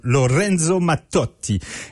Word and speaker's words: Lorenzo Mattotti: Lorenzo Mattotti. Et oui, ma prononciation est absolument correcte car mon accent italien est parfait.